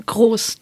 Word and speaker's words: Kroos: Toni Kroos, Kroos, how do you say it in German? – Kroos, Toni Kroos, and Felix Kroos.